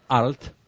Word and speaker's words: Arlt: Para los oyentes más jóvenes, el apellido se escribe A-R-L-T, Arlt.